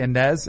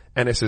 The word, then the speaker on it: Enes: I say Inez, and it's Ennis.